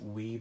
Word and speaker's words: OUAI: So the first one is Wee Body Cream. I didn't want to pronounce that because I knew I was going to butcher the name, but it's spelled O-U-A-I Body Cream.